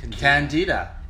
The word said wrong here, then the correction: cantina: I think it was Cantina. – It's Cantina. – Is it? – Yeah. I work in an Italian restaurant. We have a Cantina.